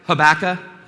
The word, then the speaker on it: Habakkuk: Now, some people uh, pronounce it Habakkuk. One spiritual giant said tobacco, Habakkuk. can remember it that way, or Habakkuk.